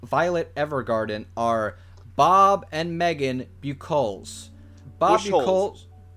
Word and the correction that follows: Buchholz: Bushels. – It's pronounced. Bu- it's Bob and Megan Bushels.